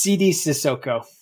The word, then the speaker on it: Sidy Sissoko: But did I say that correctly? – Yes, that it's Sidy Sissoko.